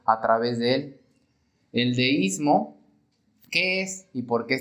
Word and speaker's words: deísmo: El peligro que ellos encontraron es el definido por la palabra deísmo. Deísmo con acento en la I.